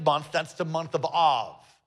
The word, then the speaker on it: Av: Everybody say Av.